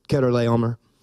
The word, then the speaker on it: Chedorlaomer: And most people that pronounce his name pronounce it Chedorlaomer, king of Elam, Tidal, king of Goim, Amraphel, king of Shinar, God bless you, and Arioch, king of Elasar.